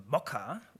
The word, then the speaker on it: Mocha: Ja, manchmal ist es auch bekannt als ECMA-Skript, weil JavaScript der Ursprung sozusagen war, und der Entwickler nannte das zuerst Mocha, also ist Englisch ausgesprochen,